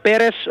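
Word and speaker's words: Pérez: Με τον Πέρεθ, όπως προφέρεται πιο σωστά αν έχω πληροφορηθεί ορθά, να κάνει γι' αυτό το δικό του ντεμπούτο, όπως και Τζούρισιτ, Σάστρε, Λιμνιός και Πεδρόσο.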